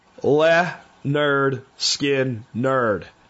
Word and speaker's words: Lynyrd Skynyrd: Pronounced Lynyrd Skynyrd. L-E-H hyphen N-E-R-D, S-K-I-N hyphen N-E-R-D.